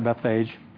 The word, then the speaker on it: Bethphage: Then when they drew near to Jerusalem, this is verse 1, and he came to Bethphage, some pronounce it Bethphage, unto the Mount of Olives.